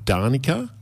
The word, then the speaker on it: Danica: It's made up of Danica, which rhymes with Marnica, and Johanna.